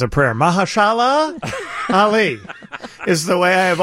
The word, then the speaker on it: Mahershala Ali: I'll see you tomorrow morning on the WGN-TV Morning News with Mahershala Ali and Viggo Mortensen. Guys, I'm excited about talking with them, but I'm just so proud that I can finally, after 2 years, pronounce the name Mahershala Ali.